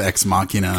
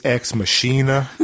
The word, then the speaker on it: Ex Machina: What did you think of Ex Machina? And can you tell the listeners a little bit about it? – Come on, man. You mispronounced it. It's Ex Machina.